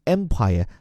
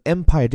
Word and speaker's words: empire: 也就这里所讲的，他将要退休的，will retire from the empire that made him Hong Kong's richest man。要从那个让他成为香港首富的帝国中退休，Empire 帝国，注意发音哈，这个 Empire 这个 a 啊发的是一个介于 a 和 e 之间的一个半开合的音，Empire。